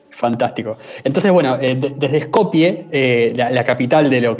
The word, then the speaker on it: Skopje: Pero la forma de decirse es la misma y es Skopje dicho por ellos mismos.